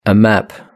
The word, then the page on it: a map: British English